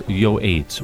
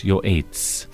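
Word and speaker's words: yoates (יועץ): Counselor's yoates, Y-O-A-T-S, yoates.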